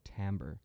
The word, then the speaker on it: timbre: And then now we're starting to talk about timbre. So timbre is kind of like the flavor of a sound. Timbre, it's pronounced amber with a T, but it's spelt like timber.